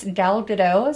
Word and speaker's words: Gal Gadot: And I guess Gal Gadot, is that how you say her name? Mm-hmm.